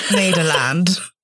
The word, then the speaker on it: Nederland: Nederland. I never heard of it that way. But I mean, that's how you say it in Dutch.